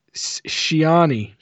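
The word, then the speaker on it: Ciani: It's got music and sound by an individual named Suzanne Chianti. Shit. It's not how you say it. No, there's no T there. – Oh, no. I listened to this a bunch of times just so I could get it right. Shiani. Shiani.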